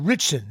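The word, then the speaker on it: Ritchson: Alan Ritson? – Big Christian. – Okay. How do you say it? Richson, that's how you say it? – Yeah, but I said big Christian.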